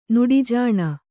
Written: ನಡೆಜಾಣ

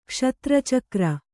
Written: ಕ್ಷತ್ರಚಕ್ರ

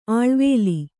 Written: ಆಳ್ವೇಲಿ

